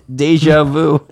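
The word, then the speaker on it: déjà vu: No, it's deja vu. It's how it's pronounced.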